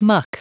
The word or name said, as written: muck